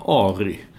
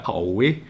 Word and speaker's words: Ari: Vad hade han? Ari. Brassen där. Det kunde de inte uttala i Kalmar. Han blev Aui. Aui.